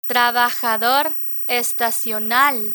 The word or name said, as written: trabajador estacional